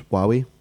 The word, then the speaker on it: Huawei: Um, so for those that know how to pronounce Huawei, that's how you say it.